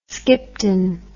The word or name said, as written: skipton